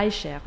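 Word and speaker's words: Eicher: Après cette chanson de Stéphane Aicher, je prononce bien Aicher, oui.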